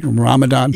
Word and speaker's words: Ramadan: Did I say it right? Ramadan. – You did, yeah.